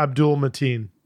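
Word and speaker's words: Abdul-Mateen: After the we've got Yaya Batim. How do you say his name? Fuck. – Who? – Hang on. – Oh, yeah, get it right. – Yeah, Abdul Mateen.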